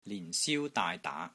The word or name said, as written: LIN4 SIU1 DAAI3 DAA2